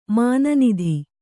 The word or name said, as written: ಮಾನನಿಧಿ